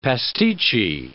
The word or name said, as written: pasticci